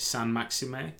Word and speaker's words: Saint-Maximin: Is it Saint-Maximin? – Yes. – Who Arsenal, I think, had been linked with. Palace had been linked with him. Saint-Maximin, I think, would be the pronunciation.